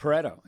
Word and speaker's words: Pareto: It's Pareto. It's he was a mathematician. I believe he was Spanish, not Italian. Pareto. P-A-R-E-T-O. The Pareto principle. – Pareto principle. – If I'm mispronouncing principle, I do apologize. I think no. I got Pareto right.